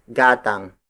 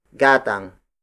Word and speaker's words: gatang: The most basic native instrument to measure volume is gatang. Gatang. Gatang. Gatang is spelled as G-A-T-A-N-G.